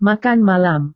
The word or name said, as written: makan malam